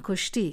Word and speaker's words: کشتی: این کلمه دچار تغییرات و تصرفاتی شده، چنان که گاه در هرات با آن گشتی یا گشتی و در کابل و اطراف آن قشتی میگویند. تلفظ درست این کلمه همین کشتی است.